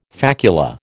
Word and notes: facula: American English

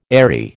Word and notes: aerie: American English

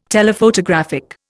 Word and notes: Telephotographic: Indian

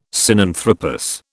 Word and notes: Sinanthropus: British English

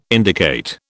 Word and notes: indicate: British English

